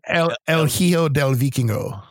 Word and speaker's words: El Hijo del Vikingo: El Hijo del Vikingo.